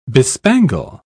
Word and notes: bespangle: British English

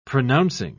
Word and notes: pronouncing: British English

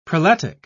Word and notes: prelatic: British English

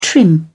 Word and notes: trim: British English

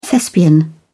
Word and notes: thespian: British English

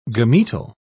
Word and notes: gametal: British English

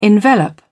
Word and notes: envelop: British English

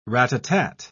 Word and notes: ratatat: British English

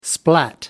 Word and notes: splat: American English

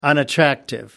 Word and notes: unattractive: American English

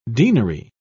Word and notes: deanery: British English

